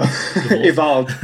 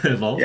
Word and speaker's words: Evold: Is that the correct pronunciation? – I think so. Evold. – Evold. – Yeah, with a D at the end, yeah.